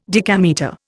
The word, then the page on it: decametre: Indian